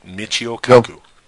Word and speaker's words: Michio Kaku: Michio Kaku.